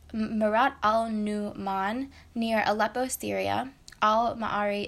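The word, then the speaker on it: al-Ma'arri: So Al-Ma'ari, by the way, it's spelled A-L and then M-A dash, not dash, like apostrophe, A-R-R-I. And I think that's pronounced Al-Ma'ari, so that's how I'll be saying it.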